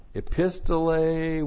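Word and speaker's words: Epistole (ἐπιστολή): And then epistole with the definite article. Of course, this is where we transliterated epistle. E-P-I-S-T-O-L, long E. Epistole.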